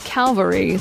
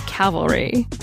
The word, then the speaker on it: cavalry: Last week, we talked about the word troops, and I mispronounced cavalry as calvary through the whole show. The word for troops who ride horses is cavalry.